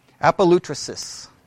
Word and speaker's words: apolytrosis: If you were to spell it out in a transliteration, it would be A-P-O-L-Y-T-R-O-S-I-S. Apolutrisis. That's how it's pronounced.